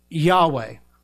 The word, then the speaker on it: Yahweh: In Hebrew, it's pronounced Yahweh.